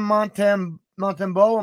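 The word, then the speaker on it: Montembeault: What's interesting to me is Sam Montembeau, am I saying that right? – Yes.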